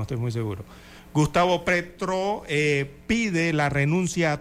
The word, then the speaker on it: Petro: Y es que el presidente de Colombia, eh, Gustavo Petro, ahora sí lo pronuncié bien, ayer me dijeron que dije Petrov. Bueno, es Gustavo Petro.